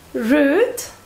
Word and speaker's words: rude: Rude. Rude es maleducado, borde o algo así. Y se pronuncia con una U larga.